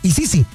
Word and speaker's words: Isisi: Básicamente se escribe I latina, S-I-S-I. Básicamente se pronunciaría Isisi.